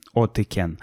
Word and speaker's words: Otyken: Пишется O-T-Y-K-E-N от Икен.